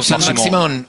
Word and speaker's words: Saint-Maximin: Pero a propósito de Maximán, ¿lo dije bien? – Correcto, Saint-Maximin. Maximo. Sí, bien. Okay.